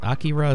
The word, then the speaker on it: アキラ: Akira is the correct way to say it, so that you felt stupid, listeners, right? You always thought it was just Akira. It's more like Akira.